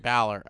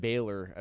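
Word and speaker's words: Bálor: Finn Balor? – Balor. – I know you said that too. – Well, you said Baylor at first.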